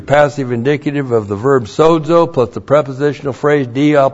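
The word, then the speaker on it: Sozo (σῴζω): Sozo looks like this in the Greek. That's S long O, Z long O. Sozo.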